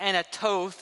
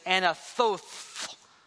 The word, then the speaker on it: Anathoth: Not Anatoth, it's Anathoth.